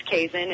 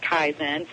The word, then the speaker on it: kaizen: There's a word, and it's a Japanese word, and it's kaizen, and some people pronounce it kaizen. So if you're tomato or tomato, either way is fine.